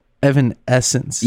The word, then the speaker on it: Evanescence: It's an Evan. Essence. – Evanescence.